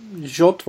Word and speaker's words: JWT: JOT diye mi okunuyor? Nedir? – Sanırım JOT diye okunuyor.